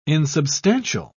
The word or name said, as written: insubstantial